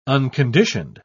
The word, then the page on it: unconditioned: British English